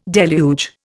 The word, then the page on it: deluge: Indian